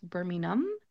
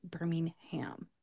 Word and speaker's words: Birmingham: I believe in England it is pronounced Birmingham, and they don't really make the H sound at the end of the word. If you are coming to the United States, definitely pronounce it Birmingham.